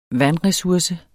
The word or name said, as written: vandresurse